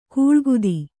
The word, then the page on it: ಕೂಳ್ಗುದಿ: kūḷgudi